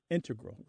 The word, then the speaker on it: integral: But the mathematicians and math-using scientists are more likely to say integral with the stress on the first syllable.